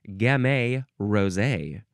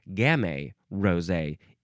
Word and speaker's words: Gamay: The example is Gamay Rosé or Gamay Rosé. Either way, it rhymes, which is pretty great.